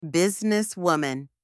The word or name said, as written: businesswoman